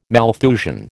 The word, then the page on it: Malthusian: American English